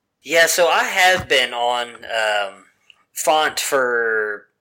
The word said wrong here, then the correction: Fant: Did I go Font and Fant? It's Font, right? – I call him Fant, but I believe it's Fant.